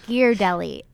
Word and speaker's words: Ghirardelli: Often, like, we look at the baking aisle and we're like, okay, Hershey's Kisses, chocolate, like Ghirardelli, chocolate, unsweetened cocoa powder, chocolate. – Ghirardelli or Ghirardelli? – I don't know. It's probably Ghirardelli, actually. It's Ghirardelli.